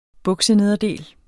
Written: buksenederdel

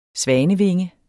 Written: svanevinge

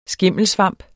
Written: skimmelsvamp

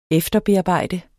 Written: efterbearbejde

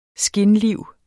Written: skinliv

